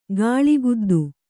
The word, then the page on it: ಗಾಳಿಗುದ್ದು: gāḷi guddu